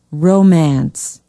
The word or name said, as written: romance